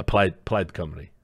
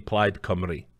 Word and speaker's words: Plaid Cymru: By the way, if any of you fuckers think I'm pronouncing that wrong, go look at the Wikipedia page. It is literally Plaid Cymru. That is exactly how you pronounce C-Y-M-R-U.